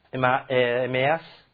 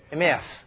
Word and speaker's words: Emmaus: In Luke 24, there is that passage, the road to Emmaus. No, road to Emmaus. Emmaus. Oh, sorry, the pronunciation.